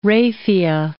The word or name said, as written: raphia